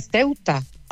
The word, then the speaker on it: Ceuta: ¿Qué dice Ceuta? Se dice Ceuta.